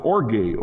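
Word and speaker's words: orge (ὀργή): It might be orge, not just thumos, but orge, O-R-G-E, pronounced orge.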